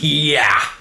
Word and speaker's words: Ye: So it's pronounced yeah.